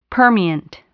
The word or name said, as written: permeant